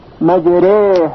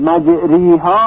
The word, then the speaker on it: مجراها: مجره مجره. اما باید دقت کرد که الف تبدیل به یا خالص نشه، مجریها غلط هست، بلکه اینطور تلفظ میشه، مجره مجره.